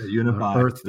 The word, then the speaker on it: UNFI: I hope I say it right. UNFI. – Yeah, UNFI.